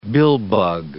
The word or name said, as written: billbug